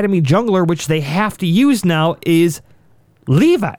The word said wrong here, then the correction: Levi: I'm sorry, he pronounces it Levy, from the Gigabyte Marines.